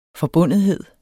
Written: forbundethed

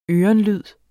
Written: ørenlyd

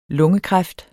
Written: lungekræft

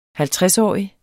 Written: halvtredsårig

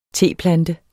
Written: teplante

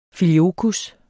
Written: filiokus

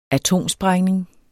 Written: atomsprængning

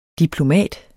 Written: diplomat

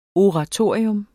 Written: oratorium

